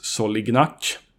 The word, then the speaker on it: Solignac: Jag har nämligen intervjuat den argentinske anfallaren Luis Solignac och ja, det är precis så hans efternamn uttalas, för det sa han när jag frågade honom.